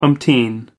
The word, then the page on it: umpteen: American English